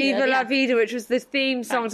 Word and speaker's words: Viva La Vida: But he played Diva La Vida, the Diva, I always say it wrong. – Viva La Vida.